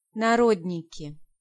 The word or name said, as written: Narodniki